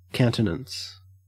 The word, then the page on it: countenance: Australian English